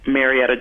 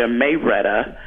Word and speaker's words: Marietta: When I was in college, I hooked up with this guy from Georgia, and they pronounce Marietta, Georgia, Mayretta.